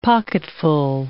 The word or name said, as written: pocketful